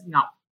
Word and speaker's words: Ngoc: So it's N-G-O-C. But, you know, kids in Canada, when I went to school, they pronounced it like Ngoc. And so they would actually make fun of me and be like, knock, knock, who's there?